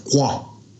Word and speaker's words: kua: So, you know, South Taranaki is like for kua, K-U-A. So North Taranaki, our old people used to pronounce it kua, right? Kua. More of a K-O-A. – Oh, yeah. – Kua. Kua aere, you know. And then down south, ku aere. They don't say the A, ku aere.